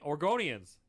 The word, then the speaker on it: Oregonians: Oregonians.